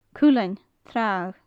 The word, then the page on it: cuileann tragha: gaelic